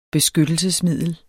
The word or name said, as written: beskyttelsesmiddel